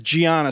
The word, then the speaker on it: Gianna: Just to touch a little sidebar here, your name is Gianna. Spell it for us. – G-I-A-N-N-A.